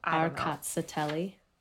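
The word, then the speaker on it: Rkatsiteli: And then, like I said, this Georgian, lots of continents in it, Rakaskikli, which is Arkatsateli. – Arkatsateli. I like that.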